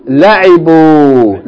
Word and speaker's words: لعبوا: هم لعيبوا. Right? Now, no long vowel on the second syllable. Huh? هم لعبوا.